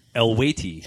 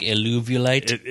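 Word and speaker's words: Eluveitie: Elwaiti. – Elwaiti. But it looks like Eluvulite. – Eluvity is the way it's spelled, but it's Elwaiti.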